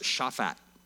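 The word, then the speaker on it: Shaphat (שָׁפַט): The word judges in Hebrew is pronounced Shaphat.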